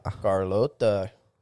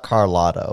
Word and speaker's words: Carlota: Carlota. – And it's not Carlotta, okay? Don't fuck up her name like that.